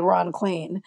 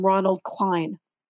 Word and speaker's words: Ron Klain: And there's no one in Washington who has any confusion about how you say Ron Klain. It looks like rain and Spain and plain yeah.